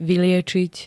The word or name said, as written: vyliečiť